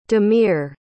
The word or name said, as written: dhameer